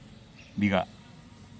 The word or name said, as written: digaa